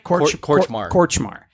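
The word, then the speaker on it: Kortchmar: Danny Kormachar. That's where this... Am I saying that right? – Korchmar. Yeah.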